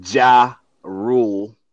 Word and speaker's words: Ja Rule: Ja Rule. Did I say it right? – Yep, he's gone.